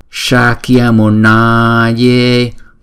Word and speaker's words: Shakyamunaye: Chữ S á, có dấu sắc á, thì chúng ta ẩn cái lưỡi lên, chúng ta đọc là sha, sha, Shakyamunaye.